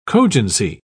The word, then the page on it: cogency: British English